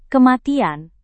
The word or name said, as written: kematian